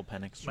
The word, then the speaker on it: Penix: Yeah, Michael Penix.